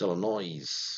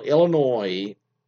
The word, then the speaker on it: Illinois: My husband pronounces the S in Illinois. So it's Illinois. So Illinois, you don't pronounce the S.